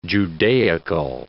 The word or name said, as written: Judaical